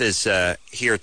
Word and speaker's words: Ishe: Now, it is Ishe, right? – Yeah, correct. – Ishe, because boxers don't always pronounce names that well, and sometimes the other guys in the contender house would call him like Ish.